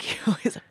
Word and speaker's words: Gilda: You have to say it like Gilda.